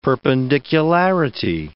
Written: perpendicularity

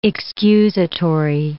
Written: excusatory

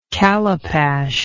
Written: callipash

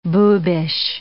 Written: boobish

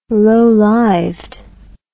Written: lowlived

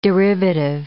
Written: derivative

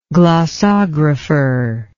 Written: glossography

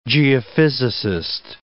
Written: geophysicist

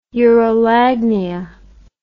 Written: urolagnia

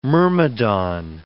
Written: myrmidon

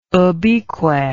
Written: ubique

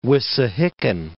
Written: Wissahickon Creek

